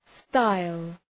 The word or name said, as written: style